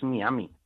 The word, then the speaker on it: Miami: deberemos decir eh, Miami o Miami. Pues esto a mí me consta que produce sonrojo a muchos, pero eh, la forma tradicional de pronunciarlo y la consecuente con el modo en el que está escrito es Miami. En español lo que se recomienda es eh, decir y pronunciar Miami. Cuestión distinta es que en inglés sea Miami, pero eh, en, español no.